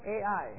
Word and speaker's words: Ai: It's pronounced Ai, A or I. But it's Ai.